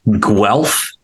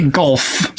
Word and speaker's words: Guelph: Guelph? That's how you pronounce that team? – Yes, Guelph. Mm-hmm. – Well, I've been saying that wrong for a long time. – I used to say Gulf.